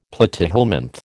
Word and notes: platyhelminth: American English

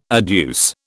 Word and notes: adduce: British English

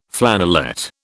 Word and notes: flannelette: British English

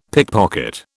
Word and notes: pickpocket: British English

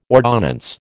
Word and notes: ordonnance: American English

